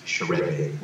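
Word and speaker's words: Scheurebe: Chorepe is spelled Cheurebe. And it's pronounced Chorepe, which will probably scare a lot of people off. But it's not that bad. Chorepe. That's how you pronounce it.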